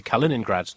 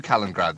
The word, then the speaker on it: Kaliningrad: The Russians have announced they're going to halt plans to deploy short-range missiles in near Kaliningrad, their Baltic enclave. – You can pronounce it better than that. You just went straight through Kaliningrad there. – Kaliningrad. – There you go!